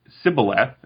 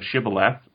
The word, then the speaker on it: shibboleth: And if they pronounced it sibboleth instead of shibboleth, then they were executed because it's like a regional pronunciation difference.